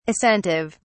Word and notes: assentive: British English

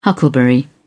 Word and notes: huckleberry: British English

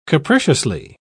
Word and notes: capriciously: British English

